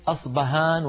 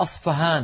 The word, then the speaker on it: أصفهان: ومنه كلمة أصبهان وأصفهان، لعلكم تسمعون ببلد اسمها أصبهان، وفي بعض الكتب تجدونها أصفهان بالفاء. لماذا؟ وهل أصبهان بالباء غير أصفهان بالفاء؟ لا، هي نفس المدينة، هي المدينة نفسها، ولكن نطقها بلغة الفرس أصفهان فهان، يعني حرف محير بين الباء والفاء.